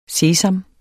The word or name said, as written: sesam